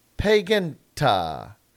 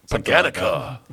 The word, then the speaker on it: Paganica: There's no T in it. – Pagan. Well, it's the C acts as a softer a softer T. – Yeah. – Paganica or Paganisha. – Paganica. – Mehi Paganica. – I would not put a T in it though. – Okay. – A T sound.